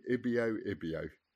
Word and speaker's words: Ibio Ibio: Ibio Ibio.